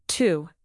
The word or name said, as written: too